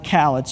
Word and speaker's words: Khaled: They said it rhymes with the word salad. Just always remember salad Khaled.